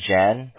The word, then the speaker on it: Jan: Yeah, so my name is Jan. That's spelled as J-A-N.